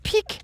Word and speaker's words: Pick: Og jeg skal lige sige, det udtales jo pik, P-I-K, og det er jo sådan et lydord for noget, der...